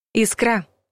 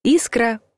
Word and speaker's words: искра: Искра. Неправильный вариант. Искра – правильный вариант. Между русским языком и мной проскочила искра. А вот и нет, потому что распространенный вариант с ударением на последнем слоге – ошибка. Орфоэпические словари единогласно называют нормой ударения на первом слоге – искра, независимо от падежа и числа.